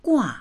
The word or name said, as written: gua4